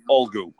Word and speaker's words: Ulgu: U-L-G-U or Ulgu.